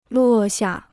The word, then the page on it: 落下: luò xià